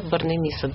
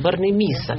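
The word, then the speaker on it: Борнемиса: Тиссен – это одна семья, Борнемисса – другой. Другая семья, и ударение надо ставить. Тиссен – Борнемисса. Потому что у нас всегда хочется Борнемисса.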